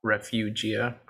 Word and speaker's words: Refugia: Can you tell us a little bit more about Refugia? That's what it's called, correct? I'm not butchering that. – Nope. Yep, you're correct.